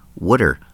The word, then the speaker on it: water: Water ice, or as the locals say, water ice, like W-O-O-D-E-R.